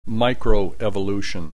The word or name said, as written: microevolution